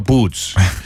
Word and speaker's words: Buds: kirjutate Buds, siis hääldame ka Buds ja, ja kui te tahate öelda Buds, siis kirjuta A nagu, ladi, mitte U, mis teil viga on.